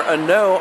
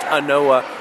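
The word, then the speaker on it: An Oa: And then for Anoa, Ardbeg Anoa, spelled A-N space O-A. So it looks like it's pronounced Anoa, but it's actually pronounced Ano.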